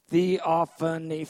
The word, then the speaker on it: theophany: It's just a theophany, four-syllable word. Sounds like, it's a four-syllable word.